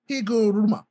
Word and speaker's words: Higuruma: Hiruguma? – How the fuck do you say your name? Higuruma. Yeah, Higuruma. Now Higurumi, Higuruma.